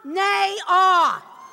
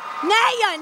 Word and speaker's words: Nayyar: Kunal Nayyar. Oh, no, no, no. Nayyar, Nayyar, Nayyar. Kunal Nayyar.